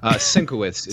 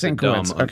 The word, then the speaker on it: Sienkiewicz: It's a matter of great dispute. Sienkiewicz is Sienkiewicz, the dumb American version.